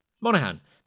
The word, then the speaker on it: Monaghan: Monaghan. – The G is silent.